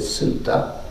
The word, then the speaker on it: sutta: Pali pronunciation is sutta.